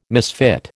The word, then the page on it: misfit: American English